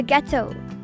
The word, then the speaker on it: gâteau: Le gâteau. For pronunciation, try putting cat and toe together with a G at the beginning.